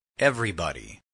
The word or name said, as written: everybody